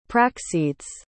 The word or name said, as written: práxedes